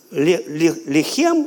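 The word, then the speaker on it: Лехем (לֶחֶם): Лехем и слово лахем. Лехем и лахем. Это два слова разных, но они пишутся одинаково, но произносятся по-разному. Лехем, лахем.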